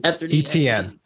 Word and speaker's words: Etienne: Bad turnovers for T-Law and Etienne, or it, how do you pronounce it? – Etienne.